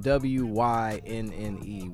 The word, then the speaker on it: Wynne: Yeah, and her, uh, her name is spelled W Y N N E Wynne.